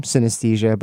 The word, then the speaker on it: coenaesthesia: The new word invention comes from combining the word cinema, synesthesia, S-Y-N-A-E-S-T-H-E-S-I-A, and another word that sounds exactly the same, synesthesia, but it's spelled C-O-E-N-A-E-S-T-H-E-S-I-A,